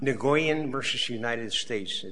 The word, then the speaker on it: Nguyen: It's Nguyen, like W-E-N, but that's how it's pronounced.